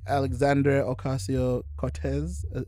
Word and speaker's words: Alexandria Ocasio-Cortez: So AOC, or you know, AKA Alexandria Ocasio-Cortez.